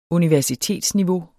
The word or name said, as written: universitetsniveau